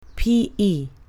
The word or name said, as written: P.E.